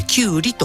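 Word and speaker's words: 胡瓜: ペルシャを表すようなコ、それからウリという字ですね、コカと書いてキュウリと今は我々読みますけれども、遠い異国から来たウリという意味です。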